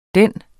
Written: den.

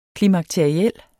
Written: klimakteriel

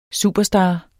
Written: superstar

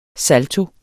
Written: salto

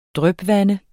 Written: drypvande

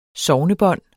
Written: sognebånd

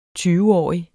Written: tyveårig

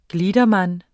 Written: gliedermann

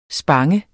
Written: spang

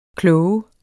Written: klog

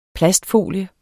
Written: plastfolie

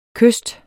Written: kyst

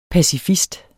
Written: pacifist

